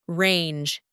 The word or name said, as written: range